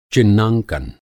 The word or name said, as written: चिह्नांकन